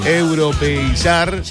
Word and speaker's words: europeizar: Desperdiciaste un primer tiempo, como que entraron distraídos los muchachos, o la pelotita para acá, pero nos queremos europizar, ¿se dice así? – Sí, puede ser, sí. – Europeizar.